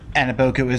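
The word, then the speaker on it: Anigbogu: With Dwight gone, maybe I'll go with, from UCLA, Ike Anabogu. – All right. – I'm not sure to pronounce his last name, but Anabogu is right.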